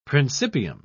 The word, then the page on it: principium: British English